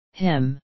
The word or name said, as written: him